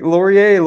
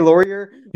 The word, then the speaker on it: Laurier: You went to University with Wilfrid Laurier. Maybe is there a French like Laurier, Laurier? – Yeah, you got it.